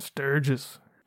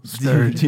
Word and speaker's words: Sturgis: Is it Sturgis? Is that right? – Sturgis. Say it right or don't say it at all. Sturgis.